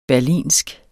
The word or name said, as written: berlinsk